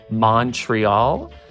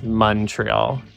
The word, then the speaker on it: Montreal: It's a pet peeve of mine when on usually American shows or movies, they're like, we're going to Montreal. So it's Montreal, Quebec, not Quebec, and not Montreal with a U.